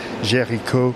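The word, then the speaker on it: Géricault: Yeah, so in French it's spelled G-E-R-I-C-A-U-L-T, Jericho, whereas for us it's Jericho, J-E-R-I-C-H-O, as Paul said. – C-H-O, that's it.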